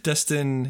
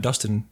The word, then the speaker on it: Destin: Destin. Yeah, Destin was a weird name. Not Dustin.